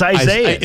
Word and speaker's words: Isaias: Well, Isaias, or however you pronounce it, is not Isaias. It's Isaias.